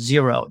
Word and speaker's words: Xero: We talk about a tax software company out of Australia, New Zealand called Xero. It's spelled X-E-R-O.